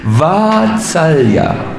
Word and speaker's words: Vatsalya: Yes, once again, the first A is a little longer than you pronounced it. Vatsalya. Yeah, so Vatsalya Jaladi, Krishna is an ocean of affection.